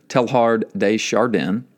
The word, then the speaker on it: Teilhard de Chardin: And his name, and I always mispronounce it, but it's Pierre Teilhard de Chardin.